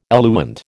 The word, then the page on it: eluent: American English